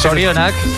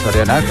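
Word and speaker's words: zorionak: Sorionak. – Sorionak, claro.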